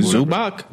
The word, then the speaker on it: Zubac: Zubac. You saying you a fan and you don't know how to pronounce Zubac? – Yeah, I didn't know who you were talking about because you didn't put the Z.